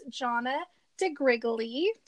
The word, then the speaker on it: Johnna DiGrigoli: Everyone, I'm so super excited to introduce Johnna DiGrigoli.